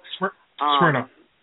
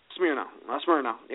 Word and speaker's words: Smyrna: Smyrna? Is that how you pronounce it? Georgia? – Smyrna. – Smyrna, yeah, there we go.